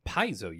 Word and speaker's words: Paizo: I can never pronounce it. Paizo. – Paizo. You got it right.